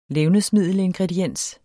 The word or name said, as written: levnedsmiddelingrediens